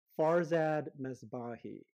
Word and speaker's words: Farzad Mesbahi: And today we have on tap Farzad Mesbahi.